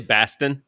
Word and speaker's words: Bastian: So uh, when he, this is uh, the Delta CEO uh, Ed Bastian. Uh, nice to get a name I can pronounce. Um, it's actually Bastin.